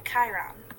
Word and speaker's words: Chiron: Yeah, Chiron.